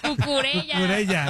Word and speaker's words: Cucurella: No, pero se pronuncia cucuyella. Ya no puedo. Cucurella.